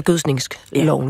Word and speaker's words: gødskningsloven: Hvad hedder det? Gødskningsloven eller gødskningsloven? Jeg vil sige, at der er lidt frit valg,